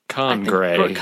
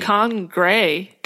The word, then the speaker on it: khaungraae: There's two A's in a row, and I never know how to pronounce that. – Oh, wait, two at the end of the word? – Yeah. The second name is K-H-A-U-N-G-R-A-A-E. Congrei. – Congrei. – Congrei. – Yeah. Congrei. Okay.